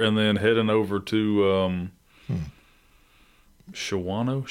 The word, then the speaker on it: Shawano: She was like, "It's Shawano." I was like, "It's spelled Shawano."